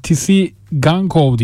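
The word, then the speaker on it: Tsygankov: Onun yanında rotayı Girona forması giyen sağ kanat oyuncusuna Tisi Gankov diye okunuyor yanlış bilmiyorsam.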